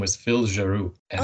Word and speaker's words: Giroux: So the way it did for me is that in the opening credits, one of the last names was Giroux with an X that you don't pronounce.